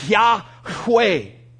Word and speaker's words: Yahweh: And so I can promise you, Jehovah is not how it's pronounced. Most likely it was pronounced something like Yahweh.